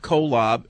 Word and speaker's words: Kolob: I'm a Mormon, and Fred is probably referring to the planet or star where we believe that God lives, Kolob. Pronounced Kolob, as in two guys lobbing together.